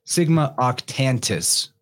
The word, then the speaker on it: Octantis: Oh, okay, let's get it right. Sigma Octantis. There's an extra... – Octantis, thank you. – Yeah, there's an extra syllable in or a consonant in there.